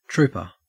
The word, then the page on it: trooper: Australian English